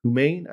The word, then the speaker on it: Humain: an AI startup called Humain, I believe it's pronounced, H-U-M-A-I-N, Humain,